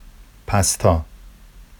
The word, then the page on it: pastɑ: Vafsi